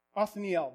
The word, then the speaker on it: Othniel: Caleb's nephew, Othniel, I think that's how you pronounce his name. Othniel, that's what it is.